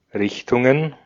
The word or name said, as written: Richtungen